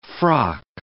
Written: frock

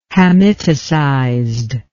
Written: hamiticized

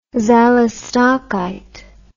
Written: xalostocite

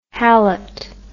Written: hallett

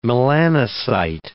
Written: melanocyte

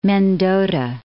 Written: Mendota, Lake

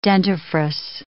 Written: dentifrice

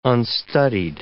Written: unstudied

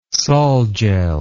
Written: solgel

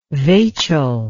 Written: vachel

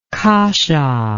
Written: kassa

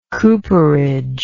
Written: cooperage

